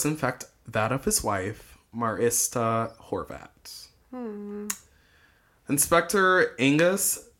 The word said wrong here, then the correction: Agus: Oh, sorry, not Angus. Agus. It's Agus Schierner.